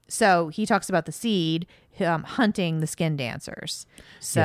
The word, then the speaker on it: Sithe: And we also learn that, okay, there are different races, and he talks about the, I think it's pronounced seed. – Okay. – It's spelled S-I-T-H-E. – Yeah, I wrote that down too. That would be a very, like, Welsh almost pronunciation.